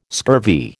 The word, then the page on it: scurvy: American English